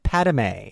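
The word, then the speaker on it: Padmé: Also, she says Padme's name wrong. She definitely said Padme, and I was like, ooh, that's an extra syllable.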